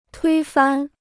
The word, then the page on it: 推翻: tuī fān